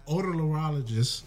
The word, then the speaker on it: otolaryngologist: All right, so gynecologist, otolaryngologist. You butchered o- it. Oto. – Otolaryngologist. There you go.